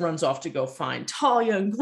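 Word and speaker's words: Thalia: As they're trying to get the kids, Percy's feeling all these insecurities about Talia. And that's how you pronounce her name, Talia.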